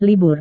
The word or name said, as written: libur